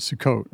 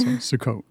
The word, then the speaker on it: Sukkot: So with Sukkot, you call it Sukkot, right? – I do. – Not Sukkot. Okay, so Sukkot. So forgive me for mispronouncing it when we open the show.